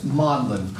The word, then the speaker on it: Magdalen: It's pronounced Magdalen College, a very wealthy college.